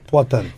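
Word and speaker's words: Poatan: The nickname is, am I saying it right, Potan? – Potan.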